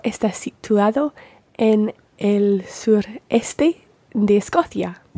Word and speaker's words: de Escocia: And notice with things like de Escocia, it runs together. De Escocia. – De Escocia. – Yeah, it's still written as two words, de Escocia, but it does run together when you're pronouncing it.